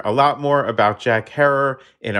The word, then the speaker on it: Herer: That is indeed how she began working side by side with Jack Herer, Rhymes with Terror,